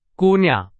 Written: 姑娘

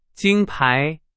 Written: 金牌

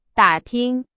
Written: 打听

